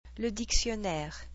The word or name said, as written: le dictionnaire